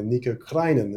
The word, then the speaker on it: Nico Krijnen: I'm your host and this time I'm joined by Nico Krijnen.